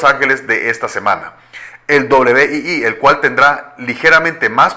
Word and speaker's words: Wii: WII se pronuncia Wii, y la consola también es conocida por su nombre código Revolution.